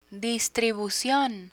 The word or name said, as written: distribucion